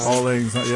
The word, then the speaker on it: Auld Lang Syne: Auld Lang Syne. – Auld Lang Syne.